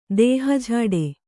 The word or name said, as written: ದೇಹಝಾಡೆ